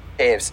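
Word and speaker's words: Toews: Blackhawks, the Chicago Blackhawks will not re-sign longtime captain Jonathan Taves in the offseason. – Taves, sorry. – It's just spelled like you have to say Taves.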